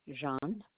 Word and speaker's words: Jeanne: Yes, my first name is Jean, and it's spelled J-E-A-N-N-E. And my last name is Bisson, B as in boy, I-S-S-O-N as in Nancy.